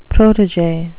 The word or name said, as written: protege